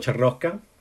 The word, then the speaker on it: Ciarrocca: So, well, full name is Paolo Ciarrocca, for anybody wondering how you pronounce that.